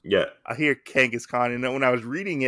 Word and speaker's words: Kangaskhan: which is the secret of Kangaskhan. Or Kang Kang did I say that right? Kangaskhan? Yeah, Kangaskhan. I said that right.